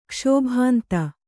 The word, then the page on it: ಕಾಶಿಬಿಂದು: kāśi bindu